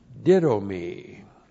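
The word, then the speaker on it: ditto me (δίδωμι): And then ditto me. D-I-D long O-M-I, ditto me.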